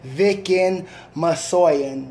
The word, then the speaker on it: Vicken Massoyan: It's my homeboy, Vicken Massoyan.